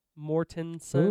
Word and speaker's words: Mortensen: Mortensen.